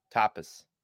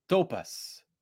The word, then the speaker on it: tapas: Tapas. – Tapas. That is the correct pronunciation of that word. He says something like topas or tapes or something super wrong.